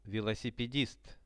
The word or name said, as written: velosipedist